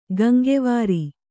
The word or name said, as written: ಗಂಗೆವಾರಿ